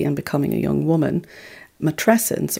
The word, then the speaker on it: matrescence: And matrescence rhymes with adolescence for a reason.